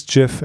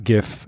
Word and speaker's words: GIF: And it is GIF, everybody, not GIF. And I say that as someone who has a soft G in his name.